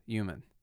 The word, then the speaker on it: human: So, first off, props to Bernie Sanders, because he's one of the only politicians out there that has finally ignored that silent H at the beginning of human. You know, people have been doing this wrong for a long time, and he's, you know, down to the letter is reminding people that human, in fact, does have a silent H at the beginning of it.